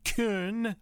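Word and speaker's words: Köln: It's spelled K-O-L-N, the actual German pronunciation or spelling of the city Cologne. K-O with an umlaut, L-N. It's something like Kuhn, something like that.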